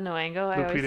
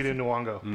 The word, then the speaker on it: Nyong'o: I'm going to say her name wrong, but you know... Lupita Nyong'o. – Nyong'o. – Thank you.